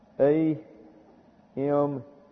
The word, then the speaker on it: Amenhotep: They're H's, but they're not pronounced. Amenhotep, Amenhotep the second.